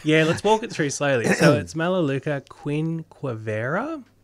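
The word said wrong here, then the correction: quinquenervia: So quinquennervia. – Oh, quinquennervia. I always drop a few syllables.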